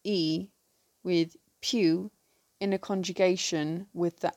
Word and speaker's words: piw: The word for who in Cornish is pew. This is spelt P-I-W.